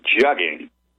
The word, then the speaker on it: jugging: And again, the name of that crime is jugging, to rhyme with mugging.